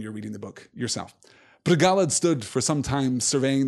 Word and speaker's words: Bregalad: I struggle with the pronunciation of this, right? Bregalad, I think, is the correct pronunciation, but because it has that Celtic kind of Scots, Gaelic kind of morphology to it, I want to pronounce it in a Scots way, and I always did pronounce it in a Scots way when I was reading The Lord of the Rings originally, where it would be Bregalad, you know, it would be a harder sound than that, and that doesn't sound entirely Entish to me, so forgive me.